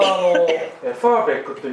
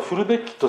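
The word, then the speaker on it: Verbeck: フルベッキー、本当はファーベックというふうに言うんですけど、ファーベックっていうのが当時の日本人はフルベッキというふうに音を移さないと発音できなかったんですね。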